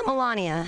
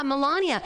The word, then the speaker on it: Melania: I miss you so much because I wondered about our new uh, First Lady, Milan, Melania? Melania. How do we pronounce her name? Melania. Yeah, Melania.